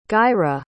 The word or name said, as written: Gaira